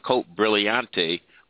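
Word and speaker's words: Côte Brillante: But it's on a street that's called Cote Brillante. Actually, it's pronounced Cote Brillante, which means brilliant hill when it's translated.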